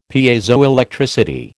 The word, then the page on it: Piezoelectricity: American English